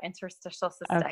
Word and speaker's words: interstitial cystitis: but is it interstitial cystitis? – Yeah, interstitial cystitis.